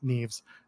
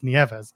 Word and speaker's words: Nieves: moving on, Tiffany Nieves, which, by the way, every time I see her name, I want to pronounce it Neves. So thankfully it's Nieves.